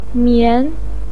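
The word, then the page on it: mian: tone 2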